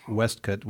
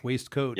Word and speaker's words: waistcoat: I love where it says, if Ballin noticed that Mr. Baggins is, and you pronounced it Westcott, which is correct as well. Waistcoat.